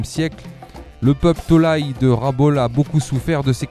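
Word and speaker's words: Rabaul: Rabol, c'est comme ça que ça se prononce, on va le dire comme ça.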